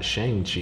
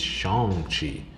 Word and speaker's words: Shang-Chi: I got a little note here in my book that I've been pronouncing it this whole time, because I know I've brought it up a lot of times on the show. Apparently, I've been mispronouncing it this whole time, and it's not Shang-Chi, it's Shang-Chi.